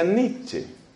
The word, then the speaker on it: Nietzsche: El apellido de ese señor se escribe de la siguiente forma. La letra N, I, E, luego la T de total. Hasta ahí llevamos Niet. Luego Z, S, C, H, E. Nietzsche, pero se pronuncia Nietzsche.